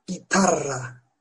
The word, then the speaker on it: guitarra: Örnek gitarra Gitarra. Belki anlamışsınızdır. Gitarra, gitar demek. Fakat guitarra diye yazılıyor arkadaşlar.